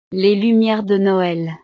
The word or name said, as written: les lumieres de noel